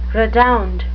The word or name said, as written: redound